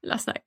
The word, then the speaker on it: Lassnack: Väldigt så här som det låter. Och det är från Tyskland. Eller så är det tyskt. Så att, ja. Man kanske uttalar det annorlunda i Tyskland. Jag vet inte. Men vi säger Lassnack.